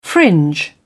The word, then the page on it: fringe: British English